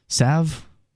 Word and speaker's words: salve: Salve? How do you pronounce that? – I mean, salve.